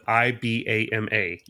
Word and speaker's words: IBAMA: we will um, spell it I B A M A, IBAMA.